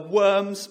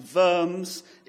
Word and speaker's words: Worms: But of course, the word diet just means parliament, and worms, properly pronounced verms, is a place.